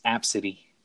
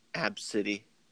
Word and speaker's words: Abcde: So, I guess the how do you say her name, though? Do you call her A, B, C, D, E? Is that what the mom does? – Nah, it's Absidy. – Absidy.